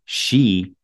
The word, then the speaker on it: sidhe: The curious Irish word, S-I-D-H-E, pronounced she, sheath, or sheathe,